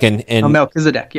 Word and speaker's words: Melchizedek: But like, so let's just take, and I may not even say his name right, but in the line of Melchizedek, is that how you say it? Like Melchizedek, yeah.